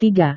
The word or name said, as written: tiga